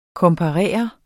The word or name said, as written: komparere